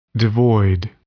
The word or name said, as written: devoid